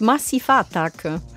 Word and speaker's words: Massive Attack: Nee, das heißt Massiva Attacke.